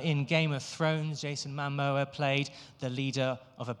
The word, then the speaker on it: Momoa: I thought to play Ganon, the King of Thieves, we can have Aquaman himself, Jason Momoa.